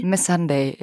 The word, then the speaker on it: Missandei: Wie spricht man sie aus? Die Freundin von Khaleesi? – Missandei, ja.